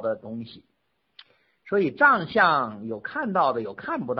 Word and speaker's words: 藏象: 藏相。 – 何光说的对啊，藏相。我们现在呢用的是这个藏，看见了吧？这个藏相，嗯，有什么区别呢？你看古代那个藏啊，很多人他不知道中医，他念成藏了，是不是像藏起来的藏啊？